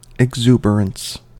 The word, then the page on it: exuberance: American English